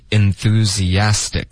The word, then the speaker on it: enthusiast: 它的发音要注意，就是结尾的 z 跟 ist，这是两个音节，中间会添加一个很轻的 e 的音， enthusiast 有一个很轻的 e， theists， theist， OK， enthusiast 四个音节。